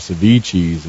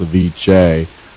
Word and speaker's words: Ceviche's: I was the executive chef at Ceviche's, and you had a lot of people come in and call it Ceviche's, and it's pronounced Ceviche.